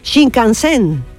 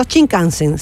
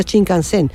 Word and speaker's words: Shinkansen: Shinkansen, o sea, el acento en el kan, no Shinkansen, sino Shinkansen.